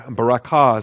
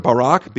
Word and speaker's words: barak: The verb Barak, B-A-R-A-K, sometimes I pronounce it Barak. In fact, I prefer the pronunciation Barak. That fixes the two vowels in my mind. Helps me to spell it better. B-A-R-A-K.